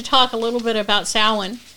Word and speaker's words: Samhain: So it is, this originally started in Celtic Ireland, and I'll talk a little bit about that, but it's pronounced Samhain. Some people call Samhain, but the correct pronunciation is Samhain.